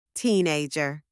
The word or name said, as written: teenager